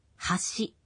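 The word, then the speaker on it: Хаши (端): Некоторые такие омонимы произносятся почти одинаково, но в ряде случаев интонация помогает определить значение. Послушайте следующие три слова. – Хаши. Хаши. Хаши.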